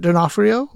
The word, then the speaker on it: D'Onofrio: it is Vincent D'Onofrio. Wait, did I say that right, Trey? – You did.